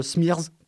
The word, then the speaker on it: Smrz: Alors, je confirme, 24 heures limite, c'est un peu le cauchemar pour le chroniqueur radio, devant épeler le nom du réalisateur qui possède, en tout et pour tout, 4 consonnes, S-M-R-Z. Alors que je prononcerais Smirz pour simplifier.